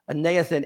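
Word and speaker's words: aneathen (ἄνωθεν): Because the word here translated again in the Greek is pronounced aneathen, and it means again, anew, or from above.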